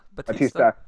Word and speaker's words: Bautista: Bautista.